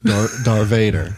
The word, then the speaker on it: Darth Vader: Darth Vader. And he puts the emphasis on the Vay, so it's like Darth Vader. Like it's in a like Dar apostrophe Vader.